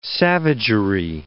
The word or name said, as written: savagery